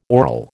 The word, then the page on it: aural: American English